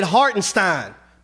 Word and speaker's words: Hartenstein: Yeah, I said it correctly. I ain't say Frankenstein. I said Hartenstein because the boy got heart.